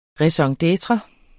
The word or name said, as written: raison d'etre